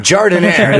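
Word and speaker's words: giardiniera: I totally mispronounced this. The last week, but it's Guardinara? – Like, Jardinera? – Jardinera. There we go. There it is.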